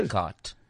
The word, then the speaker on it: Descartes: The cart. – That's the chainsaw of victory. Yay! That is correct. – Come on, Alan. – But you spell it DESCARTES.